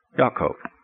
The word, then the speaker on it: Yaakov: Yaakov. It's not, there's no J. You know, I guarantee you that nobody walked around in Israel and ever got called Jacob. They were called Yaakov.